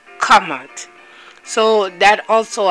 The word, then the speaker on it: Kemet: When you hear Kamat, Kamut is spelled K-M-T, K-M-T, Kamut, Kamut. Also pronounced and called, they will spell it K-E-M-E-T, but it's Kamut.